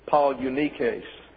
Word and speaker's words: Polynices: And I bring them to you, and I'm giving you the Greek pronunciations, not the English transliterations. Polynices is Polynices.